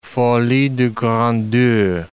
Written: folie des grandeurs

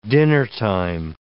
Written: dinnertime

